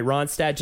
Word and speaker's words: Ronstadt: Just Rodstadt, just to name. Uh, no, I said it right. Ronstadt, just to name a few of the big names.